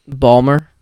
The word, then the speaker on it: Baltimore: They don't pronounce the T. – Oh, it's Balmer or Baltimore. – That's why I say Baltimore. – Yeah. – Yeah. – It's we don't have time to pronounce that T.